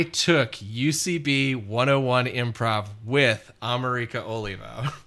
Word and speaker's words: Amerika: It's spelled like America, but it's pronounced Amerika. And I was like, Amerika.